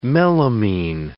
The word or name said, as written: melamine